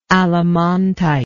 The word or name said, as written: allemontite